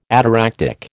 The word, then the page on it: ataractic: American English